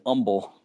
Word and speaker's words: Humble: Uh, there, there's a town a little bit north of Houston called H U M B L E. Now, how would you pronounce that? Humble. If you're from if you're from Cockney, England, how would you pronounce it? Humble. That's how they pronounce it, umble. Umble. yeah, I can't do it. The Houstonians say, I'm going up to umble.